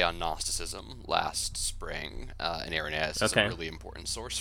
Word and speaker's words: Irenaeus: So this stuff about Irenaeus that we're going to, is it Irenaeus or Irenaeus? – Irenaeus is typically how it's pronounced in English.